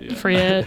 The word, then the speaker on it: Freire: I saw someone had also selected a Paulo Freire, I never know how to say his last name, Paulo Freire. – Freire text,